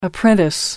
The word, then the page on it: apprentice: American English